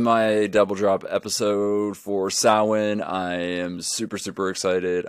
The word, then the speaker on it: Samhain: All right, kids, repeat after me. Samhain. Not Samhain. Samhain. That is how it is pronounced.